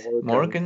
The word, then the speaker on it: Morgan: Und ähm, die Margot Elspeth, oder habe ich es richtig gesagt? Morgan. Morgan. – Genau.